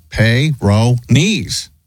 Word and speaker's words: Peyronie's: P-E-Y. I believe that would be pay, right? – All right. – In fact, yes, according to the Mayo Clinic, Peyronie's is the pronunciation. – like peyote.